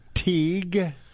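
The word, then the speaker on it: Teig: And his is Dr. Teague. – Dr. Donald Teague, T-E-I-G. I believe I'm pronouncing it correct. – I think you are correct.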